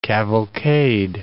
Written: cavalcade

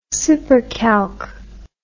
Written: SuperCalc

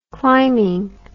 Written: climbing

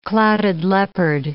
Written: clouded leopard

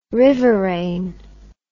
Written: riverain

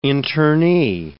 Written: internee

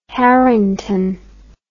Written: Harington

